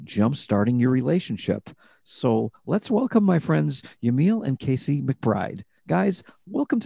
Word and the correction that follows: Yamile: Oh, that's how you say it. Jamile. – Yes. – Okay. I've been pronouncing it wrong all these years or all these months.